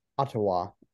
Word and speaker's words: Ottawa: Ottawa.